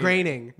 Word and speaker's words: Groening: Groening. – Matt Groening. – Groening. – Yeah, I know that because I said it right.